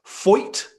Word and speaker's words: Foyt: At Triple Crown Racing on Instagram asks, having spent your first full year at, I really don't want to mispronounce this, Foyt is the name of the racing team, correct? – I'm sorry. Do you not know who AJ Foyt is? – I do. But I didn't want to mispronounce it. – You got it right.